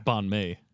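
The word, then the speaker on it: bánh mì: And how, like banh mi.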